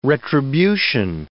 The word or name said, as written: retribution